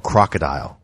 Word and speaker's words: Krokodil: It's called Crocodile, but it's spelled Krokodil, and the Russians created it.